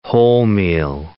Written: wholemeal

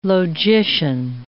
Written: logician

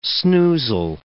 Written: snoozle

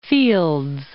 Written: Fields

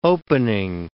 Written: opening

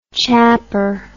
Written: chapper

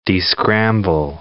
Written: descramble

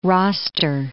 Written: roster

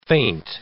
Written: faint

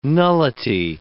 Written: nullity

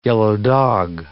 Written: yellow-dog

